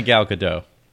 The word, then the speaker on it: Gal Gadot: No, it's definitely Gal Gadot.